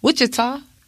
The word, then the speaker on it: Wichita: He is also the creator of upcoming film King's America Made and a co-owner of Midwest Blends Lounge in Wichita, Kansas.